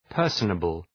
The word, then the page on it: personable: ‘pɜ:rsənəbəl